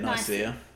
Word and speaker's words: Nicaea: Nicaea? Is that how you say it? – You say it Nicaea.